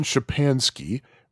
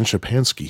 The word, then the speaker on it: Szczepanski: And they have Stan Shapansky, which is spelled phonetically, by the way, S-Z-C-Z-E-P-A-N-S-K-I. Stan Shapansky, he scored 187,880, and that was verified by referee a long time ago, June 30th, 1985.